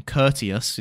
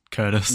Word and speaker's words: Curtius: However, another scribe, Curtius, who is his name, Curtius is probably how you say it. – I was going to say just Curtis.